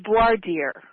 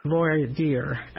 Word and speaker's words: voir dire: Another thing I see here is Dyer. Did I pronounce that correctly? – I think it's Voir dire. – Voir dire.